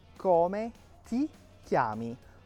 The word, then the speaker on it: come ti chiami: Não é come ti chiami, não come ti chiami. Essa é uma dica de pronúncia importante. Quando eu tenho TI, o som é ti, é um T seco, ok? Eu vou fazer esse leve sorriso com a boca, ti.